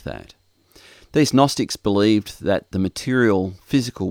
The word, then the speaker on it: Gnostics: And it came at a time when there was a group of teachers called the Gnostics, and that's a Gnostic with a capital G. In English, the G is silent, so I guess if we pronounced the G, it would sound like Gnostics.